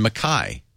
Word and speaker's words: Mackay: But I was told several times, no, it's pronounced Cairns and Mackay. And then I realized, that's just an Australian accent.